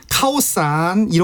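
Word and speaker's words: 카오산 (ข้าวสาร): 그래서 이제 카오산 이렇게 발음을 해야 알아듣고요.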